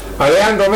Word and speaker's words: Alejandro: Come si pronuncia? – Alejandro Mendoza, siamo in prova un giocatore dell'Ecuador.